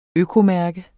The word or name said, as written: økomærke